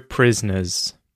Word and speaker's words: prisoners: I would say, I can say each syllable clearly, prisoner, but when I speak normally, I actually reduce this to just prisoner, prisoner, a prisoner, a prisoner, prisoner, prisoner. So, the O there just sort of disappears and that syllable disappears, prisoner.